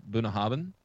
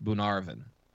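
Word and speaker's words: Bunnahabhain: And so I have pronounced it in the past Bunahabin because that's how it's spelled. It is actually pronounced Bunarvin. – Bunarvin. – Not a B anywhere in there. – Not a bit. And no AR in the middle of it either.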